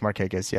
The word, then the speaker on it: Markakis: Are you sure this guy Mark Hignes is playing? – Nick Markakis? – Markakis? Oh, there he is. Nick Markakis, yes.